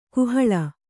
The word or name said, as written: ಕಾಹಲೆ